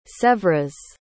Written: sevres

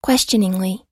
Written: questioningly

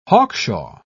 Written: hawkshaw

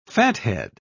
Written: fathead

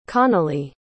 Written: connolly

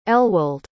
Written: elwald

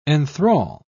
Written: enthral

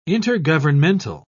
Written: intergovernmental

